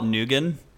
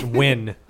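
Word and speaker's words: Nguyen: I used to call it Nugan. – It's Win.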